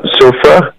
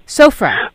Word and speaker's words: Sofra: Is it pronounced Sufra? – Uh, Sofra. – Sofra.